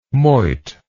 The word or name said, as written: moit